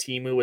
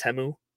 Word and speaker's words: Temu: is anybody else just learning that Timu is not pronounced Timu, is pronounced Temu? Because that was my biggest takeaway from the commercials.